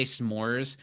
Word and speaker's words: s'more: I really liked the argument between Jeff and Britta in the camping clip of arguing over how to say s'mores, because Jeff says s'mores and Britta says s'mores. – Yeah. – And some people do say s'mores, and it is s'more. – It's s'more. – It's a one-syllable word.